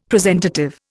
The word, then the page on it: presentative: Indian